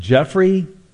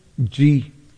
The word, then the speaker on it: Giuffre: Jimmy, is it Jeffrey? Giuffre. Giuffre. Jimmy Giuffre III.